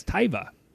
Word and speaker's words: Tuva: Have you ever heard of Tuva? Sometimes written and pronounced with a Y as Tyva.